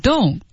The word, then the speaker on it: don't: 好，第一个地方呢是 don't 的 don't 念成 don't，它失去爆破。